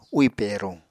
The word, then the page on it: úì peruñ: Apatani